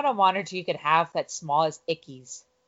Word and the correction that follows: Ackies: Am I saying it right? Ickies, Ickies, whatever. Ickies in a 75-gallon tank. – Ackies, maybe? – Yeah, Ackies are amazing.